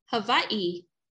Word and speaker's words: Hawaii: Just turn the W into a V. – Hawaii. – Yep. That's how you would properly pronounce it.